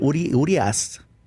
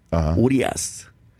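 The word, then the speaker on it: Urías: Urias. Urias, and that's probably because I got to throw the pitch at him.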